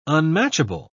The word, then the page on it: unmatchable: British English